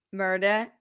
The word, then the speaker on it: Merida: Merida? – Merida, yeah.